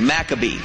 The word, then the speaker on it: Maccabee: Maccabee, I'm hearing. Maccabee is the way to pronounce this.